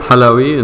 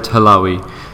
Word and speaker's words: Halawi: it's Abed Halawi in our language. In English, usually it's Abed Halawi. So the emphasis is on the middle of the last name.